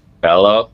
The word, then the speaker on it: Bello: Kluber versus, how do we say it? Bello? – Bello. – Bello. I didn't know if it was a Spanish double L.